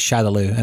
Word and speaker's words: Shadaloo: I believe it was meant to be Shadow Law, but with Japanese pronunciation of English words, for some reason it got stuck as Shadaloo, and it's been that way since.